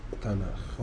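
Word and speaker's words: تناخ (תנ״ך): ماشي، يعني العهد القديم كتب اليهود في مسمى اليهود اسمه تناخ. – هو بيتنطق كده.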